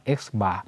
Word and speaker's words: x bar: x bar